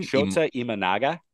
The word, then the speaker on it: Shota Imanaga: Shota Imanaga.